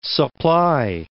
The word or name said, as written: supply